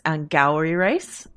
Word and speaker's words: Angourie Rice: We were mispronouncing your name, but I guess it's Angourie Rice.